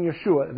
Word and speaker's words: Yeshua: And so you will hear me say the name Yeshua. That's just the Hebrew way, one of the Hebrew ways to pronounce the name Jesus, okay?